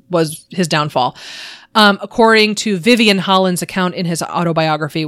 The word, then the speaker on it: Vyvyan: Vivian is spelled V-Y-V-Y-A-N, which apparently is a Welsh name, Vivian.